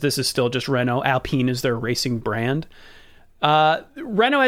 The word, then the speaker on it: Alpine: They've been renamed to Alpine, spelled like Alpine, but pronounced in French.